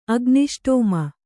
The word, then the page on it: ಅಗ್ನಿಷ್ಟೋಮ: agniṣṭōma